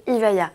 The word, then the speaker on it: Evija: C'est l'Evija, prononcé Ivaya.